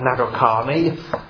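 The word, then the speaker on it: Nadarkhani: I have been following, and I'm sure some of you have as well, the case of this Iranian pastor who was pastor, I believe it's pronounced Nagarkhani, who has been imprisoned and threatened with death if he will not recant.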